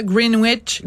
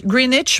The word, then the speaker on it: Greenwich: C'est le Daily Mail qui nous explique qu'il y a une université là-bas, Greenwich, Greenwich, pardon, on prononce pas le W, je pense, euh, qui a ajouté des traum avertissements.